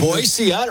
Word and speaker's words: Boise: Boise, right? – People always say it with a Z, right? – That's right. It's Boise, Idaho.